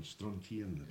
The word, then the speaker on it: Strontian: Yes, because I remember we were up there and Jack said, there's strontium over there. – Strontian. – Strontian. Yes.